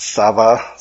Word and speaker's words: Sabaoth: This is translated in some versions as Lord, what looks like Lord of Sabaoth, but as I understand, it's pronounced Sava, so your translation might say Lord of Sabaoth.